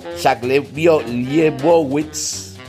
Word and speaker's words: Liebowitz: Jack Liebowitz, bien pronunciado, eh, que es el que los despide.